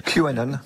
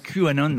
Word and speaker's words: QAnon: QAnon, je ne sais pas si je le prononce bien. – QAnon. – QAnon, voilà.